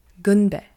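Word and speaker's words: gunbe (건배): In Korean, this word is also very similar in pronunciation. You pronounce it gunbe.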